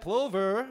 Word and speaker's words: plover: It's no coincidence that plover rhymes with stovers.